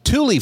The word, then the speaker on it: Tule: Bill Murray mentioned Thule fog last week, so it seemed an appropriate topic to keep WeatherBrains listeners informed. Thule fog, and that's spelled T-U-L-E, is a thick ground fog that settles in the San Joaquin Valley and Sacramento Valley areas of California's Great Central Valley.